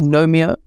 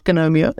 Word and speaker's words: Gnomeo: It's not Gnomeo. It's Gnomeo. – I know. As I said that, I was like, the G is silent. – I know, Dory. I know. As I said that, I was like, why did I say that? So what's wrong with me? I have an English degree. Why would I say the silent G?